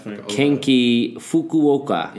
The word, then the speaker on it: Kenki Fukuoka: Left Kenki Fukuoka.